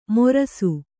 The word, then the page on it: ಮೊರಸು: morasu